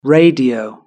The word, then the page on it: radio: British English